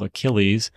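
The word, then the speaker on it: Akiles: One of the companies that sells these, or the brands, I should say, is called Achilles. I believe that's how it's pronounced. It's A-K-I-L-E-S.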